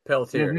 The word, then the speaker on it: Pelletier: And we had hired Paul, I'm going to say his name wrong, I think, Paul Pelletier. – Pelletier, mm-hmm.